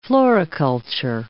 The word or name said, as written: floriculture